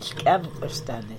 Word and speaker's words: skam kostan: And skam kostan is the right way of saying it. I didn't say it right.